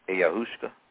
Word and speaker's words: ayahuasca: But when we deal with the issue of time, you've heard of the South American Amazonian shamans drinking a drink called ayahuasca. A-Y-A-H-U-A-S-C-A. Ayahuasca. Never heard of that? – Mm-mm. Okay, spell it again, please. – Ayahuasca. A-Y-A-H-U-A-S-C-A.